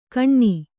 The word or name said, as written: ಕಣ್ಣಿ